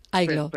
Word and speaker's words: Aygloo: Dime, por favor, antes de nada, porque como se escribe A-Y-G-L-O-O, si lo he dicho bien, o pronúncialo tú, por favor. – Aiglo.